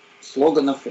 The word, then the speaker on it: слоган: Александр, помните, мы одна из самых запоминающихся слоганов или слоганов, как правильно ударение, слоган, да? – Да.